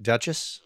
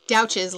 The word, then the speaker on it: Douches: The guy that you interned for originally, Alan, is it, do you pronounce it Duchess? – Douches, like couches.